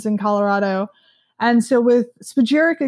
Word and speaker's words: spagyric: You know, Radical Roots, we take a different technique and we use an alchemical spagyric extraction, which isn't used by many.